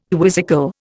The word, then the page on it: quizzical: Indian